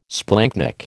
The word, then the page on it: splanchnic: American English